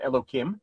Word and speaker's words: Elokim: So immediately, you know, when you have two of God's names in a row, as a general rule, we pronounce it Hashem Elokim.